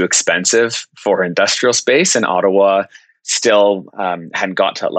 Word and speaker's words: Ottawa: So you got founded, it's in Ottawa, right? Because your office is in Canada. I probably pronounced that wrong. – Yeah, no, you nailed it.